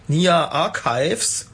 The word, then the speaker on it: Nia Archives: das war Vertical von Nia Archives.